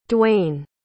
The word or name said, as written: Dwayne